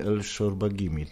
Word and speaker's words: El Shorbagi: W Bristolu trenujesz razem z braćmi El Shorbagimi, tak? Dobrze wymawiam? – Tak, dobrze wymawiasz, ale czasami generalnie więcej z innymi, bo oni dużo jeżdżą, ale tak, są na miejscu normalnie.